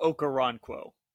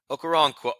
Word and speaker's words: Okoronkwo: Ogbo Okrononkwo. – Is that how you it's, say it? Obo, yeah, Obo Okoronkwo. – Okoronkwo.